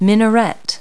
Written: minaret

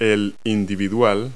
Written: placemat

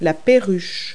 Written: parakeet

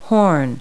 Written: horn

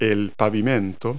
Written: pavement

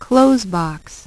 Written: close box

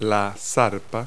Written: paw

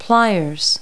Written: pliers

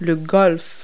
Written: golf

